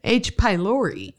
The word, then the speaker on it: pylori: Pi Bacalori. – Uh huh. You added a syllable. – What is it? – H. pylori.